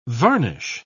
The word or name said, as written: varnish